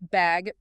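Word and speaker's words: Bagge: We're talking about Peter Bagg. – That's Bagg, pronounced like the word bag, B-A.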